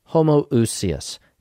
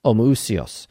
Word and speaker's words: homoousios: The word that they use transliterates into English as homoousios, same substance. Homo meaning same substance. Or you'd pronounce it in modern Greek as homoousios.